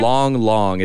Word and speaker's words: Lang: Long, it's spelled Lang Lang. – Long. – L-A-N-G, that's his name.